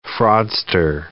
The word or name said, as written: fraudster